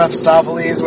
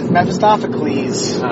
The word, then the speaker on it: Mephistopheles: Then I played, like, Nykvit, which I got to exile a Chains of Mephistopheles with Nahiri. – Oh, you said it right. – I've been making an effort to say it correctly. – Did you say Mephistopheles? – Mephistopheles.